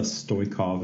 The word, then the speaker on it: Stoykov: I am here today with a guest, Vanessa Stoykov.